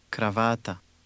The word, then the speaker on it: kravata: Did you hear the stress in that word? Read it again. – Kravata. – There is no general rule for stress in Croatian words longer than two syllables, but this is a common pattern.